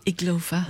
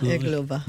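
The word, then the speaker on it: Irglová: And Marketa Iglova. – I'm glad you pronounced that. Iglova? – Iglova. – Iglova.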